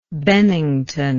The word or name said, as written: Bennington